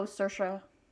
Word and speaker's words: Saoirse: Oh, Saoirse.